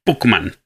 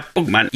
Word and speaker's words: Puckman: El nombre original de Pac-Man es Puckman, P-U-C-K. pronuncia parecido, pac pero no era Pac-Man todavía porque todavía no era para mercados internacionales, era para Japón.